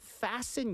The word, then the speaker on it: fasten: 네, 그 아까 fasten, F-A-S-T-E-N. 여기서 T 발음은 안 들어갑니다, 여러분. Fasten your seatbelts. 네. – 묵음인가요? – 그쵸, 묵음입니다.